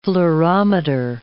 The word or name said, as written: fluorometer